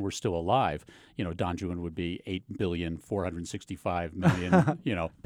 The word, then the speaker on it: Don Juan: incompleted work called Don Juan, and he did pronounce it Juan, Don Juan, of course.